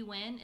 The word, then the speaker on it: Nguyen: And if you were curious, it is pronounced Nguyen, not Nguyen, not Nguyen, and not any other pronunciation you might have heard.